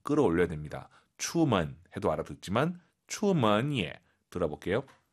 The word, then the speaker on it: 出门也: 추먼 예. 예가 올라가면 안 돼요. 예 올라간 순간 틀린 거죠. 뒤에가 일성이니까 반삼성이에요.